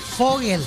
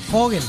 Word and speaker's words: Vogel: Fogel, porque es alemán, la B corta se pronuncia como F y la G, E, se pronuncia como G.